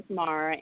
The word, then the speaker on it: Asmara: Asmara, and it is spelled J period, and then there's a space.